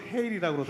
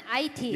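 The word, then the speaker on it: Haiti: 저희 컴패션 밴드는 이 공연을 마치고 바로 비행기를 타고 저희는 여기 발음으로 헤일이라고 그러더라고요. 헤일이. 헤일이. 예. 예 한국 발음은 IT. – 예, IT라고 하는데 예. 여기서는 헤일이라고 하더군요. 타이트 아니고 헤일입니다.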